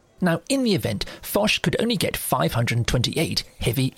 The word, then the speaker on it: Foch: So that's spelled F-O-C-H, but it's a Breton name, so it's pronounced Foch.